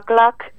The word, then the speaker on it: CLAQ: El colectivo se llama CLAC, que es un colectivo no me acuerdo del acrónimo, pero se escribe CLAQ.